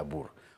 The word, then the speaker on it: Boer: Broek, que se pronuncia Brook, porque el diptongo OE en neerlandés se pronuncia U, como en Boer, que se pronuncia Bur.